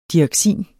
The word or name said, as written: DIOXIN